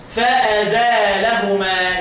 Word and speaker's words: فأزالهما: قال لك وفي فأزل اللام خفف لحمزة. هخفف الإيه؟ صح؟ أشد التشديد أهو. وزد ألفا من قبله فتكمله. هزود ألف قبل الإيه؟ قبل اللام. ها؟ تنطقها إزاي بقى؟ فأزالهما يعني أزال الشيء يعني شاله.